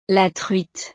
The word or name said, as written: la truite